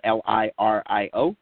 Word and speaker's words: Lirio: My last name is spelled Lirio.